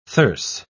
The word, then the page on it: thyrse: British English